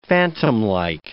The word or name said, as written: phantomlike